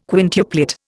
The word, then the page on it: quintuplet: Indian